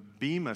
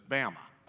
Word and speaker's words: Bema: The Bema seat is what we've always called it, but those who are Greek scholars pronounce it correctly, Bema. It's actually correctly pronounced the Bema seat, except if you live in the south part of our country, everybody just says Bama.